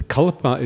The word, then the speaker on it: kalpa: It's kalpa is the Sanskrit word. In Pali, we say kappa. It's the same word, a slightly different pronunciation.